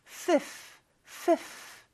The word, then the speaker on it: fifth: F 발음과 TH 발음이 다 나오는데 여러분 F 발음은 어떻게 하느냐? 윗니를 아랫입술에 살짝 댄 상태에서 푸라고 하는 것처럼 바람만 내뿜어 봐요. 이게 바로 F 발음이에요. Fifth, fifth 발음 소리 나죠? 그 다음에 TH는요. 윗니와 아랫니를 완전히 다른 상태가 아니라 편안하게 있는 상태에서 그 사이에 혀가 있어요. 이렇게. 근데 이 혀를 빼면서 스라고 발음하는 것처럼 바람만 내뿜는 거예요. 이렇게 fifth 다 바람 소리죠.